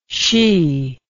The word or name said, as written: shee